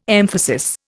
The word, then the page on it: emphasis: Indian